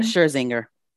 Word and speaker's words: Scherzinger: Nicole Scherzinger, that's how you say her name? – Scherzinger. – Scherzinger, thanks.